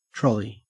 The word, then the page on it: trolley: Australian English